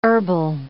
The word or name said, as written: herbal